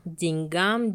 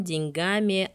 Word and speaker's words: деньгам: Литературная норма это деньгам, деньгами, о деньгах, а вариант деньгам, деньгами и о деньгах рассматривается как допустимый, однако устаревший.